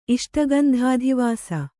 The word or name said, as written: ಇಷ್ಟಗಂಧಾಧಿವಾಸ